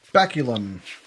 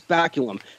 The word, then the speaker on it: baculum: Was baculum, or I can't pronounce the word properly, but baculum. – Yes, baculum.